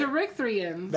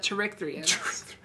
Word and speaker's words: Cherrickthrians: Cherrickthrians. – That word. – Like Corinthians, but Cherrickthrians.